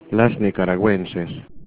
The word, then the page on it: nicaraguenses: Spanish